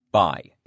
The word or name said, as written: buy